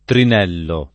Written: trinellare

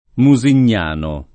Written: Musignano

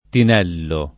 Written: tinello

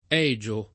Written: Egio

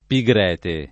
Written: Pigrete

